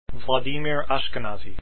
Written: Ashkenazy

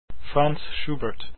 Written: schubert

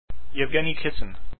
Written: Kissin

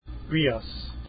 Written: rias

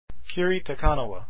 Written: Kiri

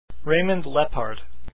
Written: Leppard

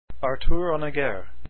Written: Honegger

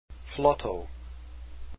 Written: Flotow

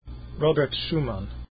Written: schumann

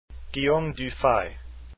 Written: Dufay

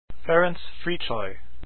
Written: Fricsay

